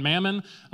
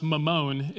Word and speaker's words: mammon: So some translations actually preserve that original word called mammon, and it says you can't worship both God and mammon. That's a very American way to pronounce that word. The original word is pronounced mammon, if you feel like feeling smarter later.